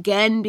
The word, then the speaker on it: Gen: So I pronounce it Gen because of my knowledge of classical Greek, that I think that that is how that should be pronounced. But there's really no rules.